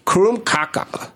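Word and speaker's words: krumkake: Krumkaka. – Mm-hmm. Kaka is like cake. – Okay. It's not poop.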